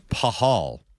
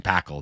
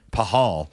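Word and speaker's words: Pachal: Now, if you look at his name, it should be Braden Pakal. It's P-A-C-H-A-L. But I went and looked because we're talking about pronunciation. Braden Pahal.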